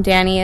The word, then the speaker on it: Dani: Danny is spelled D-A-N-I, Jensen spelled J-E-N-S-E-N,